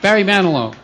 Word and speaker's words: Barry Manilow: Barely Man Enough? Barry Manilow. That's it.